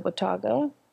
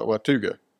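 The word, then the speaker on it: Watauga: You said it right. You're like a local now. when you know people are not local when they go to Watuga. – Yeah. – Or the Watuga, you know.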